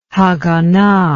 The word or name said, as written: Haganah